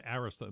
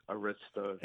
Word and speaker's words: Arista: Clive Davis takes over Bell, changes the name to Arista, and by the way, that's the correct pronunciation, right? – It is. – Okay, believe me, in these interviews I've heard it, Arista.